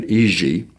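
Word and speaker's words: Jiří: His name is J-I-R-I, which they pronounce not Jiri, but Iji.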